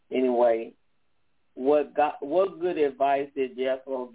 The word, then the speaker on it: Jethro: Jethro? – Jethro.